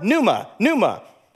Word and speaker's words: pneuma: The word spirit here is the word pneuma, like the P is silent, like in Psalms, or pneumatic. You get the idea.